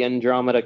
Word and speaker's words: Andromeda: The Andromeda Galaxy. I probably said that wrong. – No, that's correct. Right.